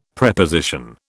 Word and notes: Preposition: British English

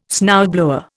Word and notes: snowblower: Indian

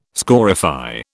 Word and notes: scorify: British English

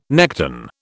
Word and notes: nekton: British English

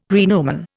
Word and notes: praenomen: Indian

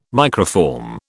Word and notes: microform: British English

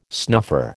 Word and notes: snuffer: American English

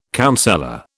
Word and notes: counsellor: British English

